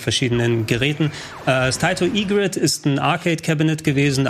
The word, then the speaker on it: Egret: Taito Egrid 2 Mini? Taito Egrid hätte ich jetzt gesagt.